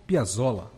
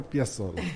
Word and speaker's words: Piazzolla: Piazzolla. Acertei a pronúncia, né, professor? – Quase. Astor Piazzolla.